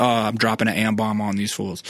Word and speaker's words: Ambaum: And it's spelled AMBAUM. – Yeah, Ambom.